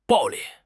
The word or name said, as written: 暴力